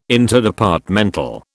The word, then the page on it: Interdepartmental: British English